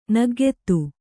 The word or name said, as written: ನಗ್ಗೆತ್ತು